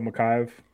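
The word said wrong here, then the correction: Chimaev: What? – Oh, Shamayev. – Shamayev. Yeah.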